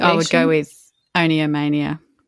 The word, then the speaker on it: oniomania: But, I mean, effectively compulsive buying disorder, and it's also called, now I'm going to say oniomania, which is probably very Australian way to say that, but it's spelled O-N-I-O, mania. Yeah. But then I think of the word onion and think, is it oniomania? – Oniomania.